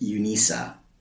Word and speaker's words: UNISA: Um, although I'm pretty sure they actually they pronounce it UNISA.